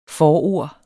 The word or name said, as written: FORORDETS